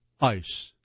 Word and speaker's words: ICE: ICE, I-C-E. Pronounce it the way you do the word that means frozen water.